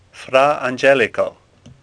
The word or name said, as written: Angelico